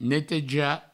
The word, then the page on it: netejar: neteja